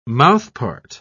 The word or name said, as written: mouthpart